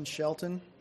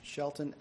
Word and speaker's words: Shelton: It's Justin Shelton. It's J-U-S-T-I-N. Shelton, S-H-E-L-T-O-N, and I'm the manager for Springwood FHP-LP, which is the owner of the Fairfield Inn and Suites.